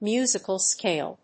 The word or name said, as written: musical scale